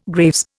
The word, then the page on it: Graves: Indian